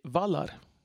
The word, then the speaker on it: Valar: Men det är alltså så att ordet som stavas så som valar ska alltså uttalas med kort a och kort l. Så borde bli vallar.